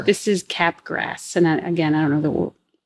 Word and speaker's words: Capgras: Capgras syndrome. – Okay. So this one is another party trick. – As opposed to crabgrass syndrome. This is Capgras.